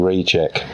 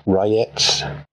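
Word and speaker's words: Rejec: Oh, it occurred to me that um, the creator of Witchburner, Long Winter, and Ultraviolet Grasslands, Luka now, I've been saying recheck. It's clearly not recheck. R E J E C. I want to apologize for that, as I understand that it's pronounced Rayets. I think I'm saying that right. Certainly closer than reject, anyway.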